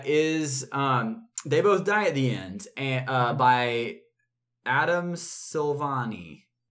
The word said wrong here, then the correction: Silvera: Let's confirm. – That's not correct. – I probably said it wrong. – It's by... Drummel. – Adam Silvera. – Silvera. Adam Silvera.